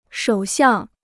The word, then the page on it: 首相: shǒu xiàng